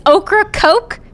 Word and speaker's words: Ocracoke: Ocracoke Island. I'm assuming that's how you say it. It is now. – It's Ocracoke. So it's like somebody like slimy gumbo vegetables and Coca-Cola.